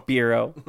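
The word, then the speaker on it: Spyro: It rhymes with hero. Zero. – Spiro.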